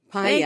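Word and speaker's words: Pa'ia: Paia.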